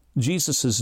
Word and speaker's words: Jesus: And so in English, we pronounce Jesus' name as Jesus.